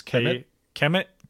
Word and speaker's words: Kmet: I'm going to make a stab at this name. Kmet. It's Kmet, K-M-E-T.